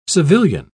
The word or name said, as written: civilian